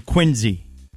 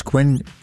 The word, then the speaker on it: Quincy: Yeah, it was founded in Quincy, Mass. And I had to pull this up in 1950. – Well, there you have it. – William Rosenberg. – And where is Quincy? How do you spell Quincy? – Quincy. Q-U-I-N-C-Y.